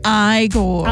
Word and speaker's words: Igor: Igor, how do we pronounce it? It's pronounced Igor.